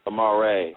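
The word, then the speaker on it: Amar'e: Amari. – Amare.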